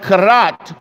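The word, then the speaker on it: karat (כָּרַת): Now, that's a Hebrew word which is pronounced karat, which means he must do.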